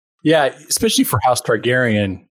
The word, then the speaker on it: Targaryen: This is actually the Cardew Gold Reserve, House of Targaryen. And Targaryen. – He's not a Game of Thrones watcher. – Targaryen, sorry.